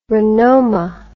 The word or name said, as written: renoma